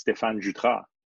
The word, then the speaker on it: Stefan Jutra: Well, it's French, so I'm French Canadian. So the proper way, really, it's Stefan Jutra. But Stefan, I know Jutra, yes. Jutra.